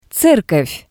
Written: zerkov

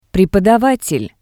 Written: prepodavatel

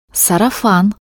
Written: sarafan

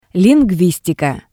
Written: lingustika

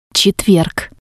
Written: tsetverg

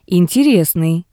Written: Interesnii

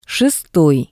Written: shestoi

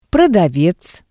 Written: prodavez

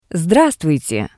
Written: Sdravstvuite

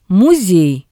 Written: musei

